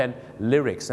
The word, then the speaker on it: lyrics: ลิริกส์